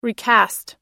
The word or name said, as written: recast